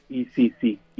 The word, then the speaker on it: Isisi: Y es todo pegadito, Isisi, y sin el acento en la I.